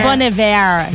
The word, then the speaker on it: Bon Iver: Ouais, c'est Bon Iver. – C'est Bon Iver. Excusez-moi. – Ça fait tellement fendant. Ça fait fendant de dire Bon Iver, mais c'est comme ça qu'il prononce.